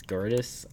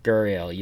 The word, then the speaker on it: Gurriel: How do you say his Gurdjieff? Gurriel.